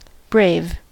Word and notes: brave: American English

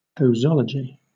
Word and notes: posology: British English

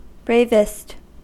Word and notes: bravest: American English

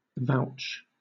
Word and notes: vouch: British English

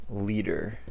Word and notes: leader: American English

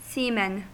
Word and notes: seamen: American English

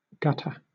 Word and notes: gutter: British English

